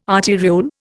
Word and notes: arteriole: Indian

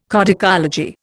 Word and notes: codicology: Indian

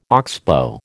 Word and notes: oxbow: American English

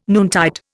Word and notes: noontide: Indian

